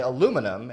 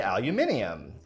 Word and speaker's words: aluminium: So we say aluminum in America. Mm-hmm. British speakers will say aluminium. And it's not just pronounced differently. They spell it differently. They spell it with an I.